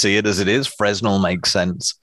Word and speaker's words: Fresnel: I have to congratulate you, on the correct pronunciation of Fresnel lens. – Why, thank you. You expected me to say a Fresnel lens. – Yep, I've heard plenty of people say Fresnels.